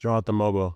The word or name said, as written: Jonathan Mogbo